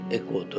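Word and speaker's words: 回向: 用語の造語になりますでそれは何かというとエコー力というものです。えーと、とエコー力というのは漢字で言えば「まあ、回」という 1 回 2 回の回ですねそれに向かうという字でエコーと読みます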